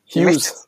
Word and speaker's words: Hughes: Wieso kann er nicht Huge oder, wie spricht man den wirklich aus? – Hughes. – Hughes. Sagt der selber, ah, mhm. my name is John Hughes, weil man weiß es nicht genau, gell?